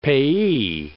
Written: payee

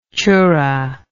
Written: choora

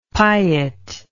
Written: piet

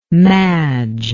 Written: madge

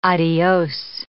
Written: adios

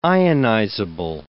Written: ionizable

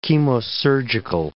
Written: chemosurgical

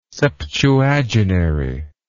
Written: septuagenary

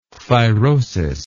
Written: thyrosis

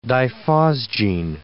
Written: diphosgene

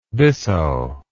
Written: bisso

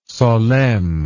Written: solesmes

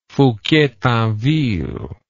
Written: Fouquier-Tinville

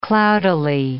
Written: cloudily

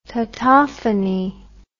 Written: tautophony

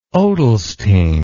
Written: odelsting

